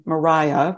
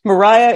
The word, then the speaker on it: Maria: Anna Maria, and she pronounced her name Mariah. We know that because she wrote a poem in which she rhymed it Faya, which I love. So Mariah